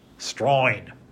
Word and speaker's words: Strine: Yeah, A-U-S-T, right, exactly. How do we hear them pronounce it? Well, we would write how we hear them pronounce it as S-T-R-I-N-E, Strine. That person there is Strine, right? Because that's how they say Australian, Strine. But they would never write it that way. They would think, yeah, A-U-S-T-R-A-L-I-A-N, Strine.